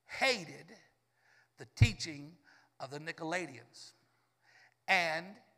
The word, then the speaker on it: Nicolaitans: Nicolaitans.